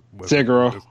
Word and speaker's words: Sekiro: So we got you know Sekiro, uh, uh is Sekiro.